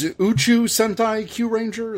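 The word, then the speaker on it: Uchu Sentai Kyuranger: We're just going to go right into the news, and I think the biggest news right now is Uchu Sentai Q Ranger. Is that how you say it? I'm terrible at pronouncing. – Absolutely correct. Absolutely correct.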